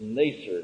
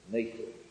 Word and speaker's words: Nasir: You really can't spell it in English. It's Nasir. It's Nun Samak Reish. Sadi. Nun Sadi Reish. The Sadi in many concordances is written TS, which is certainly not correct. The best way to write it usually is an S with a dot. Nasir. N long E, S with a dot under short E R.